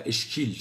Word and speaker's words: اشکال: کلمه اشکال رو در بیت اول به خاطر قافیه به شکل ممال میخونیم و اشکیل تلفظ میکنیم، اشکال.